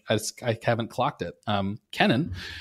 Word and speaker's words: Kennan: So I am listening right now to John Lewis Gaddis, the Yale historian's biography of George, is it Kennan or Kennan? I realize I actually don't know. I'm embarrassed to say I don't know how to pronounce. It's Kennan. Okay, Kennan.